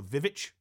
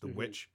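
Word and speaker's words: The VVitch: He did the, I like to call the Vivitch, even though it's pronounced the witch.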